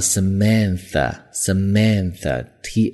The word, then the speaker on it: Samantha: 要注意这个女生的名字读作 Samantha，Samantha，T H 是有一个咬舌头的清辅音在里面。